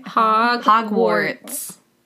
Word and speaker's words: Hogwarts: Hogwarts.